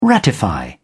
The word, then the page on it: ratify: British English